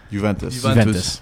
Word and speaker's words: Juventus: Juventus. How do you say it? – Juventus.